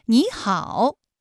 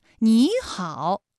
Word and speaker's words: 你好: 第一个音节的三声变成二声，比如说你好，哎，这两个音节的实际声调啊都是三声，但是我们在说话的时候，第一个音节你它就变成二声了，也就是 214+214 直接演变成了 35+214，你好。